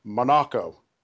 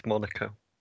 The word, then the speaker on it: Monaco: in the whale-heeled in Monaco. Is that how it's pronounced? – Not Monaco. – It is if you're Stirling Moss. Otherwise, it's Monaco, generally.